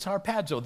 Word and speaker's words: harpazo: Nobody, I mean, people look at it, and if you can pronounce it in the original language, it's harpazo, the snatching away.